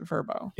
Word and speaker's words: Vrbo: And they want us to pronounce it verbo.